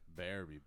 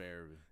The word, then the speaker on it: Berube: Berube, bitch. – Berube. That's how you say it for real? – It's Berube.